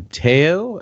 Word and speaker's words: Teo: It looks like Tao, but it's not Tao. It's Teo. – Uh, yeah, I'm Teo.